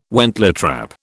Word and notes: wentletrap: British English

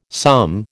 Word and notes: some: American English

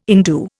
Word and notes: indue: Indian

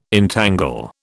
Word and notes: entangle: British English

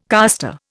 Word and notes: caster: Indian

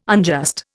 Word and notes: unjust: Indian

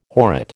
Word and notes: horrid: American English

